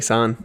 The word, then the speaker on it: San: Am I pronouncing it right, San? – Yeah, exactly, San. Yeah.